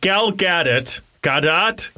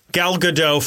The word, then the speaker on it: Gal Gadot: You know how they fought like Gal Gadot? – It's Godot. – I don't think it is. – I've heard it said. I mean, it could be. I could be wrong. – The T is pronounced.